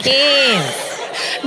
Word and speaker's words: Cairns: How do you pronounce C-A-I-R-N-S? – Cairns. – Cairns. – Cairns. Up in the back of the nose. – Cairns. – Cairns. – No S on the end.